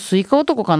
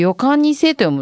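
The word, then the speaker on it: 酔夏男: 最初スイカ男かなと思ってたんですけど、これ予感にせと読むらしいですよ。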